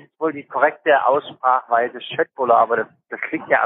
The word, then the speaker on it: köttbullar: Das heißt nicht Köttbullar, weil das ja nicht korrekt ausgesprochen ist, sondern Schöttbullar.